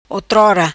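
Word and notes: Otrora: patua

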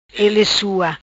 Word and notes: Ele sua: Patua

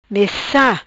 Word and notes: Messa: patua

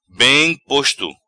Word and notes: Bem Posto: patua

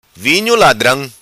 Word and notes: Vinho Ladram: patua

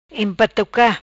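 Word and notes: Empatuca: Patua

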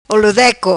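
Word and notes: Olo Deco: patua

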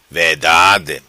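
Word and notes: Vedade: patua

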